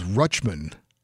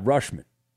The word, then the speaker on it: Rutschman: They said on the highlight it was Rutschman. – Not Rushman.